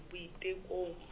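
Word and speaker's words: Boitekong: Can you please make Buitukong, not Buitukong. – Oh, Buitukong. – Yeah. – Can you spell it? – B-O-I-T. – Okay. – E-K-O. – Okay. – N-G, Buitukong.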